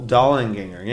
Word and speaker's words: Dollinganger: Dollinganger, yes. I said it right.